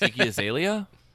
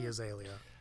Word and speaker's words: Iggy Azalea: Iggy Azalea. – Ah, damn it! You did it right. Mm-hmm. Yes, mm-hmm. Iggy Azalea.